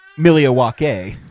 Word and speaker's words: Milwaukee: It's actually pronounced Milwaukee.